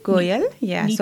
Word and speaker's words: Goyal: This is Neetu Goyal.